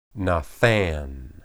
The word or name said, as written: nathan